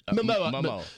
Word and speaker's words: Momoa: Momoa.